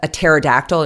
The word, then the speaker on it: pterodactyl: And there's a joke in English that what does a pterodactyl, a flying dinosaur, and the DPP have in common? The first P is silent.